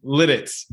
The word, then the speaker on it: Lititz: It's spelled Lititz. How do you say it? Lititz? – Lititz, yeah. – Lititz, okay. Well, it's not the inappropriate version of it.